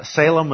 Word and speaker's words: Salem: And just so it's clear, Salem, which was later named Jerusalem, Salem is how we pronounce it in English. The Hebrew is more in the nature of shalom, okay, meaning peace.